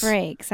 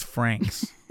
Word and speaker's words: Frakes: Frakes. I always say his name wrong. – Yeah. yeah. You think he's a hot dog, so it's Franks.